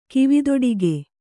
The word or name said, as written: ಕಿವಿದೊಡಿಗೆ